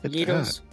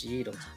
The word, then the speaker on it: gyros: It's not gyro, it's gyros.